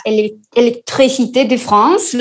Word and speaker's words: Electricité de France: Entonces, a ver, hay una empresa que es la Electricité de France, ¿lo pronuncié bien, Gonzalo? – Correcto, bueno, correcto.